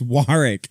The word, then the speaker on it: Warwick: And they're like, how do you say it? Warwick? He's like, no, it's Warwick. Warwick. The W is silent.